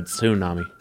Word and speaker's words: tsunami: I think it's the base language where it comes from. It is more of a tsunami.